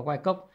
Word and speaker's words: Wyckoff: Wyckoff Wyckoff hay đọc là Wyckoff